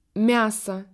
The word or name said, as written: viande